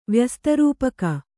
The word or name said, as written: ವ್ಯಸ್ತರೂಪಕ